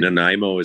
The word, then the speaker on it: Nanaimo: Nanaimo is how you say it, and the Night Owls are the team, and you know, we're excited about bars because you have an alternate logo.